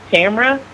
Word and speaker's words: Tamara: So my name is actually pronounced Tamara, like camera with a T.